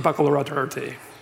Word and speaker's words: baccalaureate: It's pronounced baccalaureate. No one knows how to pronounce this.